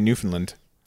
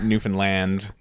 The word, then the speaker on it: Newfoundland: Yeah, and they were just having a big thing about how to pronounce because they all want to say Newfoundland. – Newfoundland. – The Brits. – Newfoundland. – And they were having a big chuckle over that it's Newfoundland, and everybody had to catch themselves.